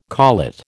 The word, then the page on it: collet: American English